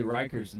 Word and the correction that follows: Reichard: name wrong week in and week out. You're obviously not preparing properly. Are you anxious? Willie Reichard.